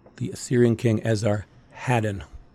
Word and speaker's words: Esarhaddon: But in Swedish, we say Esarheddon.